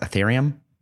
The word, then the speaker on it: Ethereum: Ethereum, right? That's how it's pronounced. Ethereum. Yes.